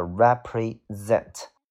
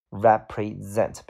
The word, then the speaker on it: represent: 第一个单词叫做 represent，注意这个单词的发音啊，这是一个动词，所以它的重音往后放，叫做 represent。 represent，比如说这个东西代表了什么呀？